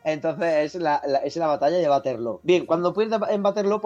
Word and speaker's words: Waterloo: Que se pronuncia Waterloo, porque es un término holandés, una palabra, no sé si flamenca, holandesa. Lo que quiero decir es que no es Waterloo.